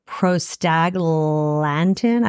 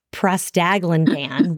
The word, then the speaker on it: prostaglandin: The reason why I like this is because it doesn't contain an ingredient called prostaglandin. I don't know if I'm even saying it right. P-R-O-S-T-A-G-L-A-N-D-I-N.